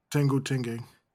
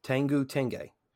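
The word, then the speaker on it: Tenjou Tenge: Tenjho Tenge. – Tenjho Tenge.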